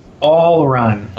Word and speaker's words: Ool Run: How is it pronounced? – All Run. – Okay, but it's spelled OOL Run.